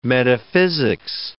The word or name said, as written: metaphysics